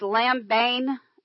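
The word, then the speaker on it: lambano (λαμβάνω): This word receive right here, this particular word is the word lambeo. It's L-A-M-B-A-N. The emphasis is on the B-A-N, long O. So it's lambano.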